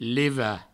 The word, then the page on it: levat de: provençal; levade